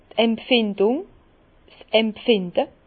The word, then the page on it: d' Empfindung, 's Empfinde: Bas-Rhin; Herrlisheim